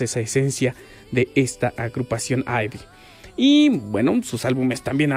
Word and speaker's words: IVE: Y de ahí también ahorita vamos a hablar de su nombre, porque Ivy, porque se escribe I-V-E. Podríamos decir que sí se pronuncia Ivy,